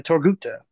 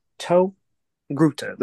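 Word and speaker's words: Togruta: So, Ahsoka Tano, the main character in this series entitled show, is a Torghuta. Torghuta. That is how you say the race of her people.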